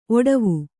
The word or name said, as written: ಒಡವು